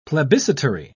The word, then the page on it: plebiscitary: British English